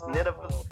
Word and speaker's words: Nidavellir: Nidavellir Nidavellir Nidavellir I forgot how to say it Nidavellir with the with the dwarf.